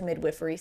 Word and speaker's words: midwifery: No, so we hired a midwife, specifically a certified nurse midwife, which means that she is also a nurse, and then went to midwifery school. That's how you say it.